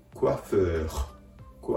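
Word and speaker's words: coiffeur: この場合では、フをような形で、クワフー、クワフーと発音してあげるところがポイントです。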